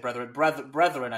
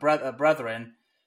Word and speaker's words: brethren: and I can't even I said brethren, I should say. Mispronounced it, but brethren.